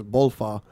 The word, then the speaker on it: Balfour: Maybe he wrote it like that because that's how you pronounce it, is Bolfa.